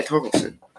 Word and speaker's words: Torkelson: How do you pronounce his last name? – Torkelson? – Yeah, Torkelson.